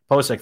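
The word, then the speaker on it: Pocic: Ethan, I always say his name wrong, Pochick, the center for them. Pochick, thank you.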